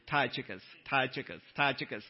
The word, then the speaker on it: Tychicus: Tychicus is how you pronounce that name.